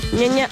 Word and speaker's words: Ñaña: Hay que pronunciar bien. Ñaña.